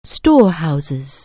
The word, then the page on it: storehouse: anglais